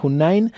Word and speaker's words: Hoonine: Vayan y búsquenla, se escribe H-O-O-N-I-N-E, Hunain, así la pueden buscar en todas las redes.